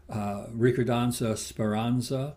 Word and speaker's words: Ricordanza Speranza: And the first quintet, I'm going to mispronounce this, but it's Ricordanza Speranza. – You got it.